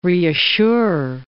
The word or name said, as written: reassure